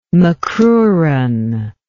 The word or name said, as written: macruran